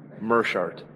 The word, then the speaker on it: Meerschaert: Meerschaert. – Oh, full name, please. – Oh, Gerald Meerschaert.